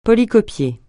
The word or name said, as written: polycopier